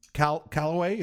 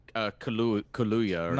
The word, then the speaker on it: Kaluuya: Daniel Calloway, is that what it is? – Kaluuya.